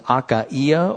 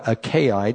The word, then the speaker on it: Achaia: The northern Grecian area was known as Macedonia, and the southern Grecian area was known as Achaia or Achaia, depending on how you like to pronounce that.